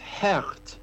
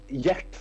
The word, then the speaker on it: Gert: Det är en 23-årig eh, belgare Eh, och hans namn uttalas Härt. Däremot, om eh, historien stämmer, men eh, det roligaste då. Robert Berg trodde att han eh, hette Gert först.